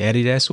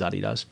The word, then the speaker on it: Adidas: So depending what continent, I say Adidas or Adidas or Adidas. Obviously in America, I'm Adidas, um, but our founder was Adi Dassler. Um, right. So the official pronunciation is, is Adidas. But if I hear you say Adidas, I love it just as much.